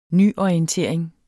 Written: nyorientering

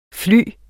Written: fly

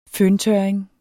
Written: føntørring

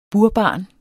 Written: burbarn

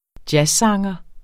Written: jazzsanger

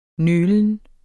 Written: nølen

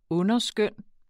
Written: underskøn

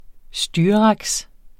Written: styraks

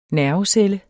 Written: NERVECELLE

